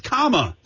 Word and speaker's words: Kamala: It's Kamala, like the punctuate Kamala, not Kamala or Kamala.